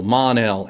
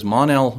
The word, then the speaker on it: Mon-El: Mon-El, and you have to say it right, because Mon-El, much like Scott Shaw, has to be said as though there's an exclamation point at the end.